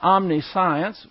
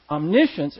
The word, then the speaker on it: omniscience: And that is, it's not omniscience, which is what it looks like. It's pronounced omniscience, and it's talking about the knowledge of God, which is unlimited.